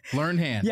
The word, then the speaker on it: Learned Hand: You said Learned Hand. – Right. – Did I say that right? – Yeah. Learned Hand.